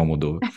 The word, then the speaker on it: Almodóvar: E é até uma das coisas que eu estava assistindo que falava que depois de você ver a Penélope Cruz com o Almodovar, falei errado de novo, mas é isso, o Almodóvar,